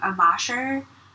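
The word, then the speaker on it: Amacher: Amasher, A as in Apple, M-A-C-H-E-R.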